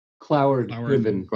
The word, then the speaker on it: Cloward-Piven: You're also responsible for the pronounce this for me. Is it Collard-Piven Manufactured Crisis Strategy? – Cloward-Piven Manufactured Crisis Strategy.